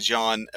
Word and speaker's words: Can: It's spelled Can, C-A-N, but in Turkish, a C is a J, a soft J sound. So it's actually Jan Evernol.